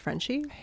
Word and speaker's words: Frenshe: It's spelled F-R-E-N-S-H-E. French, but it's apparently pronounced Frenchie.